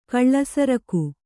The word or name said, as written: ಕಿಳ್ಸೆರಗು